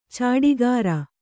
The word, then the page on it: ಚಾಡಿಗಾರ: cāḍigāra